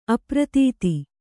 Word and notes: ಅಪ್ರತೀತ: apratīta; Kannada

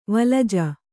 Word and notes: ವಳಜ: vaḷaja

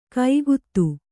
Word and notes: ಕಾಗದ: kāgada

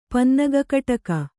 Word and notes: ಪನ್ನಗಕಟಕ: Kannada

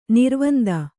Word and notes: ನಿರ್ವಂದ: nirvanda; kannada

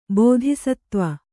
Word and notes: ಬೋಧಿಸತ್ವ: bōthisatva

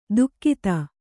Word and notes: ದುಕ್ಕಿತ: kannada; dukkita

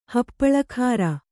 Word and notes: ಹಪ್ಪಳಖಾರ: Kannada

